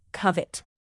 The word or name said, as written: covet